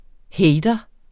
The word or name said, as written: hater